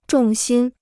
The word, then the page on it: 重心: zhòng xīn